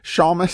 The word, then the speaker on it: Seamus: He writes, "Hi, regarding the pronunciation of Seamus, I grew up hearing it with a long A, but most of the old time radio and movies tend to pronounce it with the short A. I'm thinking both are correct.